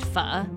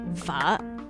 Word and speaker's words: pho: It's actually pronounced Pho, by the way. Say it with me. Pho.